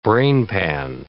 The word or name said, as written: brainpan